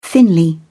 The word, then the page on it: thinly: British English